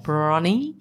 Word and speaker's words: Bronnie: So Bronnie is like Bonnie with an R in it. Bronnie. And Ware as in like Bunnings Warehouse.